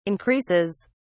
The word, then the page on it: increases: British English